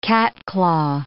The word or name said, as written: catclaw